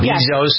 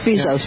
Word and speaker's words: Bezos: Amazon's strong earnings pushed the value of Amazon stock up to the point where Jeff Bezos, is that how you pronounce his name? – Yeah, I think so. – Bezos. – Yes, Jeff Bezos.